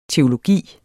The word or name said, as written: teologi